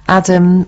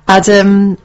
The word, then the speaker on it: Adham: Boys, Petra Courtright, Adam, which is spelled A-D-H-A-M, Faramaway, and Hannah Perry. – Hold on. Give us that again. – Adam, spelled A-D-H-A-M, Faramaway.